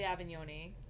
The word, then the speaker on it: Avignone: Ashley Avignone, which I just learned how to say her name based on she did this video where she was like, "Hi, I'm Ashley Avignone and I'm here with this brand."